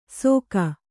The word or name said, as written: ಸೊಕ